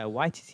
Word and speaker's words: Waititi: Is that how you say his name? – Yeah, Waititi.